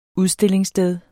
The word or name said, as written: udstillingssted